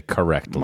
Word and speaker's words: correctly: Collectly. – Did you say that right? – I said collectly, and I meant to say correctly.